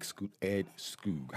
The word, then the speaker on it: Skoog: So for me, when I see that Ed, I think about like Ed Skoog. How do you say Ed's last name? S-G-O-O. – Skoog. – S-K-O-O-G.